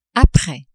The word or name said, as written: apres